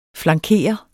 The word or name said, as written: flankere